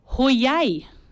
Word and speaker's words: Heuer: Tag's correct. Hoyay. – Hoyay. – Hoyay. – Oh, Tag Hoyay. I've been saying Tag Heuer for. – No. – Oh, Hoyay.